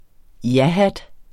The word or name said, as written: jahat